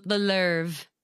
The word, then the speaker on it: Louvre: Oh God, I'm going to pronounce this horribly, but the Louvre, the Louvre by Lorde, it's like L O U V R E. I don't think it's like the Louvre.